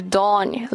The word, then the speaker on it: dawn: 大家注意一下这个 dawn 的发音啊，a w 是发 o 长元音，不要发成 ow 啊，那个是 d o w n down，这个是 dawn 黎明。